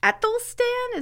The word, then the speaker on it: Æthelstan: Like, I guess the official first king of England was Æthelstan? Is that how you pronounce it? – Mm-hmm. Yeah, that's how I've always pronounced it.